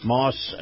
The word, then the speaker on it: Maus: By the way, this portion of the program brought to you by our friends at Moss Nissan. Moss is spelled M-A-U-S,